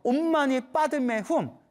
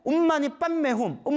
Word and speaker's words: 옴마니 반메훔: 옴마니 밤매움 옴마니 반메훔이 옴마니 반메훔 산스크리트어 발음으로는 옴마니 빠드메훔이래요. 옴마니 빠드메훔 근데 빨리 발음하면 옴마니 빤메훔 옴마니 빤메훔 이렇게 된대요. 티베트는 옴마니 뱀메훔 옴마니 뱀메훔 뱀메훔 한대요.